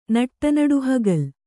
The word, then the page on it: ನಟ್ಟನಡುಹಗಲ್: Kannada